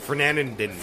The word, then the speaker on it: Fernandina: And Fernandina. How do you say that? I'm hurting myself. – Fernandina.